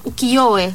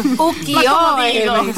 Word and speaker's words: ukiyo-e: es de los ukiyo-e, se llaman. No sé si lo pronuncié bien, le pedí a una amiga mía, ¿cómo se dice? – Ukiyo-e.